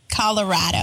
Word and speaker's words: Colorado: I mean, they say it's because Colorado is rad, so it's Colorado.